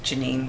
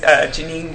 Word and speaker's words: Genine: It's just my name, Janine Ware. – Yeah, that's why. – Twitter is Janine Ware Acts. But Facebook and Instagram, it's just Janine Ware. – Okay, how do you spell it? – It's spelled G-E-N-I-N-E and the last name W-A-R-E.